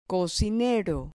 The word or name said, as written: cocinero